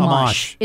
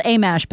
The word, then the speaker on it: Amash: Amosh. – Yeah. Amosh? Okay. – And he needs to be mashed. – Yeah, he does. So it's spelled Amash, but it's Amosh.